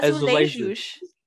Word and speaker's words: azulejos: Yeah, azulejos. – Yeah, excellent. – I always forget that in Portuguese, the J is actually pronounced instead of silence.